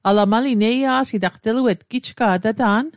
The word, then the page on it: gijga'tata'n: Mi'gmaq